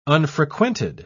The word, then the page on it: unfrequented: British English